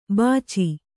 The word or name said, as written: ಬಚ್ಚು